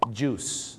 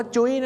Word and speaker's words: juice: juice นะครับผม juice ไม่ได้ออกเสียงว่าจุ้ยนะฮะ juice นะครับผมท้องเสีย juice juice เลยนะครับผมน้ำผลไม้ไม่ใช่จุ้ยนะฮะต้องออกเสียงว่า juice นั่นเอง